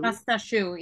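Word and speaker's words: pâte à choux: pasta Did I say that right? Pasta choux? Pasta shoe? – Pasta shoe, yeah.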